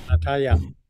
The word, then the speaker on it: Natalia: And Nadia, do you have anything you'd like to say or share? – Yes, Natalia. – Natalia, I'm saying it wrong.